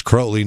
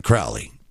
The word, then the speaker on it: Crowley: It's Crowley, not Crowley. Just like you think it would sound, but the way it's spelled.